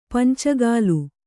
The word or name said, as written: ಪಂಚಕೋಲ